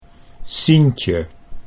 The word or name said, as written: Sientje